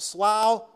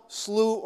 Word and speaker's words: slough: it can be pronounced slough, slough, or slow.